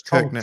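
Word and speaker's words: Kirkenes: Kitsnes? I don't know how to pronounce that. Sorry, Kirkness. Yeah, it's Kirkness. Tromsø and Kirkness.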